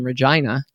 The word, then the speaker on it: Regina: And I did a trip where I went and visited a friend of mine that I actually met professionally who lived in Regina.